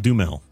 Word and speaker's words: Duhamel: Is it pronounced Duhamel or Demel? Duhamel.